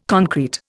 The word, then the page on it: concrete: Indian